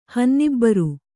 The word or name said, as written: ಹನ್ನಿಬ್ಬರು